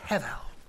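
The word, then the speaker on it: hevel (הבל): In Hebrew, it's pronounced hevel, two breathy consonants.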